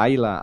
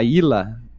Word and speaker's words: Ayla: Quando tu vai pra, pra pré-história, tu encontra a Ayla, a Ila, cada um pronuncia como quiser, né?